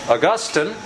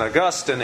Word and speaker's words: Augustine: No less a person than Augustine, and by the way, the way to remember how to pronounce his name, Augustine is a city in Florida. Augustine is the church father. So if you want to be precise and proper, that's how it is.